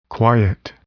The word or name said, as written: quiet